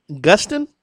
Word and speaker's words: Gustin: It's Pete Gustin? – Yep, Gustin.